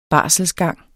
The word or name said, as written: barselsgang